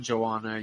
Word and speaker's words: Joanna: Joanna, yo mama, whatever. – Oh, look at the southern guy getting the Polish pronunciation right.